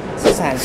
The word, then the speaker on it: Cézanne: Cézanne.